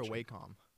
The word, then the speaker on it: Wacom: Is it Wacom or Wacom? – Wacom, Wacom. Depends where you're from or how you say it. I don't know. It's like Nikon.